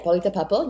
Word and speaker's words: Paulita Papel: So I'm, Paulita Papel, you pronounce it right.